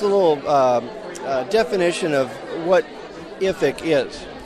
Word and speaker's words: IFIC: I'm speaking with David Schmidt, and David is President and CEO of the International Food Information Council, or we might pronounce it IFIC here in this interview, David.